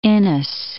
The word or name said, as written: Inness